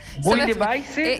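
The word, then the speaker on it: Bouillabaisse: Luego el tercer tiempo, Connie, va a ser, mira, este, la pronunciación es mega complicada, disculpa. Es Bouille de Baisse.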